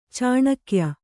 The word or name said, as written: ಚೀಣಿಕಾಯಿ